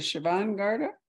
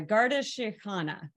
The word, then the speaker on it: Garda Síochána: What is it? Is it the Siobhan Garda? – It's a Garda Síochána, which translates as Guardians of the Peace. – Guardians Síochána. Okay. – Garda Síochána.